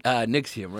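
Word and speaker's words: NXIVM: Nixium, right? – Yeah.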